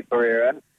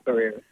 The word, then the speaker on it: Pereira: Well, I say Pereira, but it's a Portuguese last name. – Is it? Yeah. – I don't think I say it properly. – Yes. – So I say Pereira.